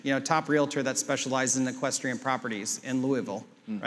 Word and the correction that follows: Louisville: Or Louisville, sorry, mm-hmm. for my Kentucky folk. – Yeah, it's Louisville. – Louisville. – The eye is silent.